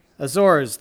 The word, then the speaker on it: Azores: Am I saying that right? The Azores? Out in the middle of the Atlantic Ocean. Azores, thank you.